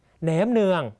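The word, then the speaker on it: nem nướng: แหนมเนืองนะครับเรียนภาษาเวียดนามห้ามพูดว่าแหนมเนืองต้องออกเสียงว่าแหนมเนืองนะครับ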